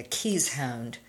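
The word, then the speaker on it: keeshond: My landlords have always had caissons. They are a more American version of the pronunciation would be a keys hound.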